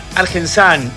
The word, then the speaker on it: Argensun: Argensun, y muchos dirán Argensan, y es real, Argensan. ¿Por qué Argensan? Es una mezcla de Argentina con Sunflower, que es como en inglés se pronuncia semilla de girasol con filtro.